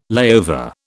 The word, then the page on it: layover: British English